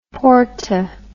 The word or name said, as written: porta